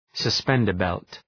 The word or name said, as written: suspender-belt